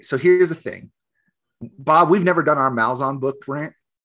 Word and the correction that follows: Malazan: It's pronounced Malazan. – Whatever. Malazan. Okay.